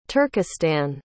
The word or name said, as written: turkistan